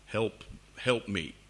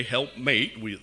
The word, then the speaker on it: help meet: Most of the time you hear these two words together as if it's one word. Help meet. Okay? You probably thought I was saying it funny or saying it wrong. and there is that word today, help mate.